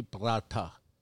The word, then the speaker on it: paratha: Is that how you say it? – With a T. – Aloo paratha? – P. Prata.